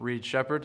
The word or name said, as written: Reed Sheppard